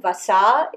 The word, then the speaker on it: vasa: And the Sanskrit word for lard is vasa. With a long A at the end, vasa, yeah.